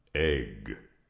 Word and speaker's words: AIG: And how would you pronounce those 3 letters? – Well, I guess egg. But... – And after we've spent $175 million in a campaign so viral it would literally make your head spin, you join the 210 million Americans who pronounce it egg.